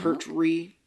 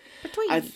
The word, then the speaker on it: Pertwee: Pertwee. I'm saying that wrong. Pertwee. I've seen him in different things,